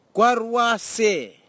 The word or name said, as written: kwarwasei